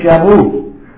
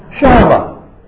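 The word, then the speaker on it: Shammah: I'm glad nobody said Shamu. No, Shama, not the killer whale, but this is one of his sons.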